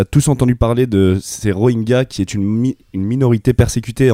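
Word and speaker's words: Rohingya: Tu nous fais un point sur les Rohingyas, je le prononce bien Ouais, c'est ça, c'est les Rohingyas.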